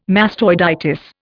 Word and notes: mastoiditis: Indian

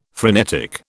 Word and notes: phrenetic: British English

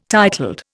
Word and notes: titled: Indian

